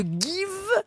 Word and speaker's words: Geev: Alors, vous êtes le cofondateur de Give. Ça s'écrit G-E-E-V.